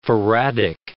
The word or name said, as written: faradic